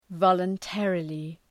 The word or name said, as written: voluntarily